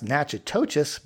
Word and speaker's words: Natchitoches: And for those of our listeners that are not from Louisiana, Natchitoches is spelled nothing like it sounds. This is all part of my learning to pronounce these towns right. It is spelled Natchitoches. So you would think it would be pronounced Natchitoches, but it is not. It is pronounced Natchitoches. – Wow. It's even less syllables than it looks.